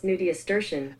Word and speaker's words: nudiustertian: Hey, Google, how do you pronounce Nudiustertian? – That's pronounced nudiastertian.